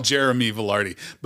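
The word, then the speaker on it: Jeramie Velarde: It's called Teacher's Pet, and it's under Jeremy Velarde, and you have to spell it, right? So JERAMIEVELARDE. – All one word? – No. Oh. Jeremy Velarde.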